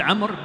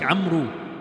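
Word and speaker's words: Amr (عمرو): And the name Amr, it is spelled Ain Meem Ra Waw. It is pronounced Amr, but it is spelled like Amru.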